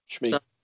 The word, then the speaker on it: Shmi: Shmi.